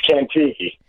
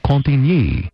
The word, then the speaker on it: Cantigny: Google says Cantigny. – Cantigny. – Right. And that's because it's pronunciating it from the French version. We're in America. Cantigny. – Cantigny.